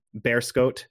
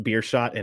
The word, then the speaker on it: Beerschot: coming from Beerschot, uh, that team gets pronounced beer shot an awful lot.